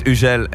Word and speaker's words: Hugel: Hey, it's Hugel and you're listening Energy Bremen Hit Music Only.